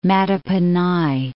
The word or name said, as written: Mattaponi